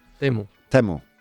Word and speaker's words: Temu: A potential bright spot is Temu, or Timu. How do you pronounce that actually, here in Spain? Temu. Temu.